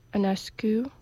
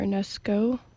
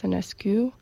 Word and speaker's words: Enescu: I'm going to start off with George Enescu, or Enesco in France, but I think the Romanian pronunciation is Enescu, who has an octet in C major.